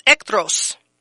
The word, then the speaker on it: echthros: So it's ekthros. Ekthros. It is spelled E-C-H-T-H-R-O-S.